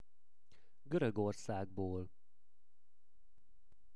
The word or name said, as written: Gorogorszagbol